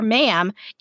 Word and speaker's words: ma'am: And after that, you can call her ma'am. It rhymes with ham.